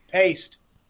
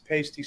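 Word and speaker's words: Paiste: It's paste. The guy, the owner, Eric Paste, pronounces his name Paste. The company, it's Eric Pasty. – The company is referred to as Pasty sometimes by some people, but when he refers to his own name, it's Paste.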